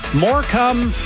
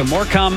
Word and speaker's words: Morecambe: I'm told it's pronounced Morecambe, UK. Is that true? It is Friday. So Morecambe, UK.